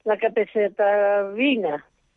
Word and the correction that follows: capecitabina: si lo digo bien. Capecitabina.